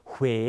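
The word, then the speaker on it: Huế: ส่งผลให้เวลาเราออกเสียงเนี่ยเราต้องหอปากด้วยนะครับดูตัวอย่างคำแรกพร้อมกันฮวาสังเกตปากผมห่อด้วยนะฮะฮวาแปลว่าดอกไม้นะครับยกตัวอย่างอีกคำหนึ่งนะครับคำว่าเหวหรือที่คนไทยเรียกว่าเวไม่คุ้นใช่ไหมฮะคำว่าเหวคำว่าเมืองเวนะครับภาษาเวียดนามที่ถูกต้องออกเสียงว่าเมืองเหวไม่ใช่เมืองเว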